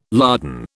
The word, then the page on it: lardon: British English